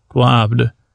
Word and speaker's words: globbed: Philip, tell him about the blob, and uh, it's, it, uh, he says, no, it's a globbed with a silent D. Actually, it's not silent though, globbed.